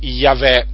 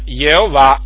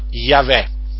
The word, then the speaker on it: Yahweh: il tetragramma che appunto viene pronunziato Yahweh persino dagli ebrei, eh? non Jehovah ma Yahweh. Dunque il nome, il nome Gesù significa Yahweh salva e questo naturalmente indica che Gesù era colui che Dio ha mandato in questo mondo per salvare il mondo.